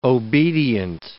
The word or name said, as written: obedient